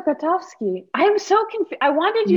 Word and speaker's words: Grotowski: Grotowski.